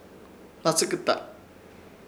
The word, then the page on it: Hnatsɨkɨta: Ishir; Chamacoco